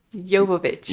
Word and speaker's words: Jovovich: And instead of Mila Jovovich or... – You're saying it wrong. – What did I say? – There's no S in her name. Jovovich.